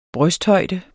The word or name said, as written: brysthøjde